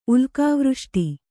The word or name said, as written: ಉಲ್ಕಾವೃಷ್ಟಿ